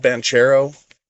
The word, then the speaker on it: Banchero: Um, now it's spelled Banchero, but from everything I've heard, it's Banchero is his is his last name.